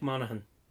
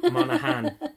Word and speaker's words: Monaghan: Dominic Monaghan. – The G is silent? – Yeah, Monaghan. – Monaghan? – Monaghan. – I know, I was just saying it like that. – Yeah, like County Monaghan in Ireland.